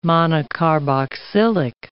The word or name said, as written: monocarboxylic